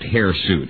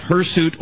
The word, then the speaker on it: hirsute: It's actually not pronounced hairsuit. It's pronounced hirsute or hirsute, and it's spelled this way.